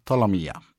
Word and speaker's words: Ptolemaea: I o ucieczce mówi piosenka Tolomija. – Tolomija. – Tak. Słowo, które jest tytułem tej piosenki, wymawia się Tolomija, a jakby je przeliterować, to byłoby P-T-O-L-E-M-A-E-A.